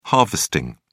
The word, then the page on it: harvesting: British English